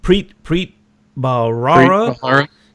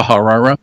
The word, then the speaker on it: Bharara: And it took, you know, Preet Bharara, how do you say his name? Preet Bharara. – Yeah. Bharara.